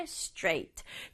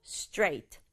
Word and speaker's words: straight: 그럴 때 쓸 수 있는 말 straight. 주의할 점은 straight 다섯 박자 아니라 한 박자로 straight 해볼까요? Straight. 이렇게 발음해야 된다.